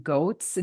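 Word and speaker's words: Goetz: but it's called Malin and Goats. It's G-O-E-T-Z.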